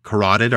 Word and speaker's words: carotid: She'd suffered two stab wounds to her neck and her left carotid artery had been severed. Said it right, Riley. – You said it right. It's a hard word.